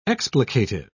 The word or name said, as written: explicative